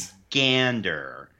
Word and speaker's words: Michigander: It's pronounced Michigander. You gotta put the accent on the right syllable. – Goodness. – There. Michigander.